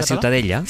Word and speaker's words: Ciutadella: ¿cómo se pronuncia? – La catalán? Ciutadella.